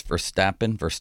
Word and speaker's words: Verstappen: Max Verstappen.